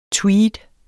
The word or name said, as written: tweed